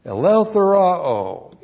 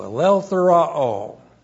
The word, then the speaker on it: Eleutheroo: Eleutheroo, E-L-E-U-T-H-E-R-O, long O, Eleutheroo.